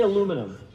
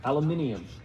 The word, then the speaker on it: aluminium: Say aluminum. Not say it like the British. Okay, you've all watched the Marvel things, right? So now I can't say it the British way. Aluminium.